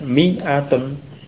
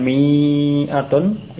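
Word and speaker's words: mi'atun (مِائَةٌ): Ya, berarti di sini alif itu tidak dibaca, tidak diucapkan, hanya ada dalam penulisan. Ya, dan juga dibuktikan dengan ucapannya dibaca pendek, mi'atun. Kasrahnya mi itu dibaca pendek, bukan mi'atun, ya, ataupun mi'a, bukan seperti itu.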